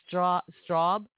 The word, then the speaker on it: strobe: I could see, you know, the most beautiful thing was those straw lights. I don't know. They call it straw lights. I always say it wrong. Strobe. Strobe lights.